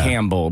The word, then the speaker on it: Campbell: Or, excuse me, I'm sorry, then the other one, C-A-M-B, like yeah. Campbell. Because you say Campbell, like the P is kind of silent. – But a lot of people don't know that. – But it's word. – Camp Bell.